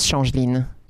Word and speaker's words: Changeline: It was with my French accent, so it was Changeline changeling works and people been calling me change.